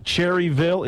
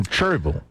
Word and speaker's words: Cherryville: It's not Cherryville, it's Churville? – It's in Churville. – Churville. – Lincolnton, my hometown, is about 15 minutes from Churville. It is spelled like Cherryville, but the locals do not pronounce the L. It's sort of like Clinton. There's a T in there, but it's not pronounced in Clinton.